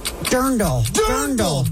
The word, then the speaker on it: Dirndl: It's drindle. No, Dirndl. – Dirndl is the correct answer. Dirndl got you the win. That is a correct answer, not a dreidel. – Damn you, Dirndl.